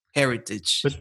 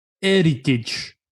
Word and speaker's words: heritage: That Busquets to Messi pass is football heritage. – But you do it wrong, because Jose Mourinho never pronounces the H. He says football heritage. – Heritage, yes. – Yes, football heritage, but, indeed, correct.